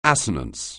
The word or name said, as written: assonance